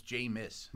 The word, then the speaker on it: Jameis: That dude from Nebraska, his name's not James, by the way. I've made that. It's Jameis.